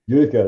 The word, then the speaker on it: Utica: Utica, that's right.